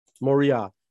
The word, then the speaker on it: Moriah: What's the pronunciation? – Moriah.